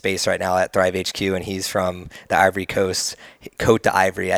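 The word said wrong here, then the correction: Côte d'Ivoire: Cote d'Ivoire, yeah.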